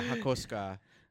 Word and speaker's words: Hakosuka: Yeah, yeah, Hakosuka is how it's pronounced in Japanese, or okay. That's how the Japanese pronounce it.